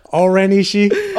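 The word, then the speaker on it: O-Ren Ishii: Oren Ishii.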